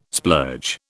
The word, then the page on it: splurge: British English